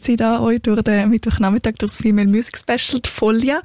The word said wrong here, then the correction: Follia: Folia, jetzt habe ich es gerade falsch gesagt, stimmt. Oh, das habe ich gerade schwätzen. F-O-L-L-I-A schreibt man. Und Folia, hast du mir verraten, sagt man.